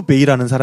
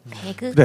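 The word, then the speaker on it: Bey: 그 투르크어에서는 지자를 원래는 묵음으로 해야 된다고 해요. 그래서 우리 이제 옛날에 배우신 분들은 이걸 모두 그냥 영어식으로 읽어서 베그라고 써진 문헌들이 많이 있을 건데, 뭐 그냥 투르크식으로 읽으면 지자를 묵음으로 하면은 베이라는 발음이 난다고 합니다.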